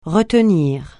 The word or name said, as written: retenir